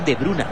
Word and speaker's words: De Bruyne: Kevin De Bruyne, o Kevin De Bruyne, como él mismo explicó que se pronunciaba, y Jeremy Doku abandona la cancha para darle espacio a Nicolas Raskin y Dodi Lukebakio.